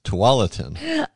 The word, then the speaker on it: Tualatin: See, I am saying it wrong. It's Tualatin.